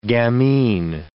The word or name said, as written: gamine